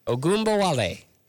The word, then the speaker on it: Ogunbowale: Ogunbowale.